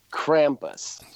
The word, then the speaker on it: Krampus: Krampus. – It is Krampus. – Krampus. – It is Krampus, dude. – It's a fucking Krampus. – Who has seen Krampus? – I've seen Krampus.